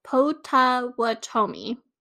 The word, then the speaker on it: Potawatomi: Potawatomi. – Let me look at what you're saying so I can see your mouth. Potawatomi.